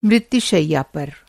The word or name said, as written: शैया